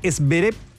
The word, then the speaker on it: Zverev: Y en otras noticias internacionales, Alexander Zverev, como dice él que se pronuncia, se quedó con el título del US Open.